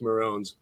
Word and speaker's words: Moröns: He's like, it's spelled with an umlaut and pronounced Morons.